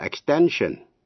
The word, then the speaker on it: extension: Thế nhưng hai động từ, to extend, và danh từ là extension. Comprehend là hiểu, danh từ comprehension. Thì cái tiếp vị ngữ shen đọc là shen, extend, extension.